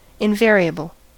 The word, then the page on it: invariable: American English